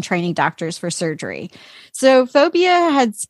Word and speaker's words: Fovea: Fovea. – Fovea.